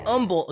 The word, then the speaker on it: Humble: In humble... Okay, listen, I need... First off... I'm going to help all of you. Okay? The name of this town is Humble. The H is silent. – Spelled H-U-M-B-L-E.